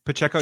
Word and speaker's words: Pacheco: Last one I like, Isaiah Pacheco. I think I'm saying his name right. Am I saying that right? – Pacheco, yeah.